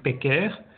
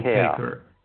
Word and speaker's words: Peycker: So I'm not going to request the original pronunciation, which is Peycker. I'm okay with you calling Peycker. – Me Peycker.